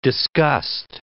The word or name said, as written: disgust